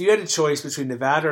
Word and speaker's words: Nevada: But, I mean, look, the Nevada, I always forget how to say it, and I was always corrected. – It's Nevada.